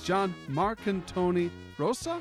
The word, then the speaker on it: Jon Marcantoni Rosa: Today's guest is John Marcantoni Rosa.